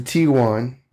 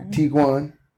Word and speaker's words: Tiguan: We ended up finding the Tiguan. Is that how you say it? Tiguan.